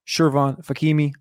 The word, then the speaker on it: Shervon Fakhimi: You can find Shervon on Twitter at Shervon Fakimi.